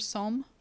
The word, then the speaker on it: Somme: Is that pronounced Somme? – It's a S-O-M-M-E, the river Somme.